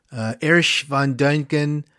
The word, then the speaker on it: Erich von Däniken: Erich von Däniken, that's the German pronunciation.